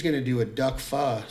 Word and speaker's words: pho: I like the way you say it, pho. – So I think that's the correct pronunciation.